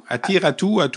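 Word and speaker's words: Aatu Räty: Comment se prononce son prénom? – Atu. Atu Ratu.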